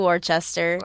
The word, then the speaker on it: Worcester: They all do always Worcester. – Always do Worcester.